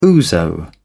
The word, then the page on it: ouzo: British English